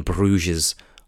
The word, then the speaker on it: Bruges: He has done Seven Psychopaths, also with Colin Farrell, and In Bruges, you don't pronounce the S, do you?